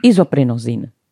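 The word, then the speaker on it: Isoprinosin: Pane profesore, my jsme před chvílí hovořili o isoprozinu, nebo isoprosin, jaká je správná výslovnost? – Izoprinozin. – Ano. Dobře, už to budu říkat správně.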